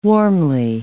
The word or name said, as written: warmly